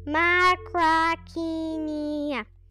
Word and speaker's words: Macrauchenia: Macrauchenia.